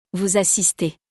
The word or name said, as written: vous assistez